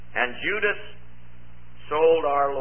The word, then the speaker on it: Judas: It's pronounced Judas. That's the way it was pronounced at a later time.